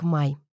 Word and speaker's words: Мэй: Имя Мэй по-английски произносится как Май.